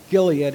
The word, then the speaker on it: Gilead: From that song, and in the song, Gilead is the correct pronunciation.